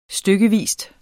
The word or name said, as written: stykkevis